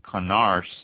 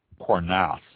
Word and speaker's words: Cornas: The ones on the western bank, the Cote Rote and the Condru and Saint Joseph and Connars Cornas, I believe is pronounced, all set on the western bank, with the Cote Rote and the Cornas making only red wines.